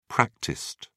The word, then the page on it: practiced: British English